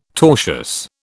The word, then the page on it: tortious: British English